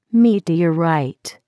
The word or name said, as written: meteorite